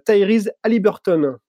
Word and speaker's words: Tyrese Halliburton: Euh, et, justement, on va commencer avec euh, Tyrese Halliburton. C'est bien ça, euh, Flo Exactement. Tu l'as bien prononcé, bravo.